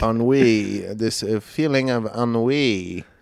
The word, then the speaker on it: ennui: Ennui? – Uni.